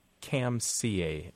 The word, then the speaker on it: Kamsia: transliterated into Roman characters as Kamsia, uh, sia," K A M S I A, um, with a long A.